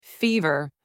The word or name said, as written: fever